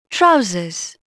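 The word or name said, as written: pants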